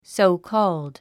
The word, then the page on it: so-called: sóukɔ́ːld; ソォコーゥド